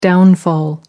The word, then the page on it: downfall: American English